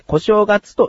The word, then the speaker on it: 小正月: それはこの日が小正月であり、小さい正月と書いて小正月と読みます。